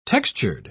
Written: textured

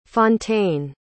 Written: fonteyne

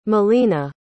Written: molina

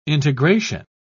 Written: integration

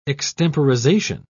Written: extemporization

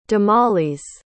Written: damalis

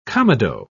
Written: comedo

